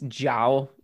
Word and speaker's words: Zhao: So it's Zhao, like J-O-W is the way I'm seeing it pronounced.